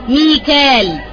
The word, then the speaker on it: ميكال: مثال مثلا كلمة ميكال، كلمة ميكال تقرأ بكذا كيفية، ميكائيل، ميكال، ميكائيل، تمام.